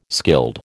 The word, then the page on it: skilled: American English